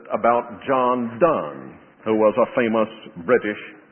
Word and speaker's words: Donne: I called him John Donne all this time until I heard an Englishman who carefully told me that you pronounce D-O-N-N-E, Donne.